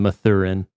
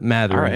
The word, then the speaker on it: Mathurin: I've been calling him Mathurin for some reason. I think it's a grab bag of pronunciations. – It's because I lived in Mass when one of my best friends was from Methuen. And so that's how it got stuck in my head. But Mathurin, right.